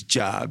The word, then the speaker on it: Dia: The first thing I learned is the proper non-English pronunciation of his last name, Dia, spelled D-I-A. – The correct French pronunciation is Dia because Senegal was a French colony and we speak French. But most people here say Dia.